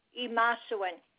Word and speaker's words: Imasuen: Okay, and then the last name, honey, is Imasuen. I-M-A-S-U-E-N.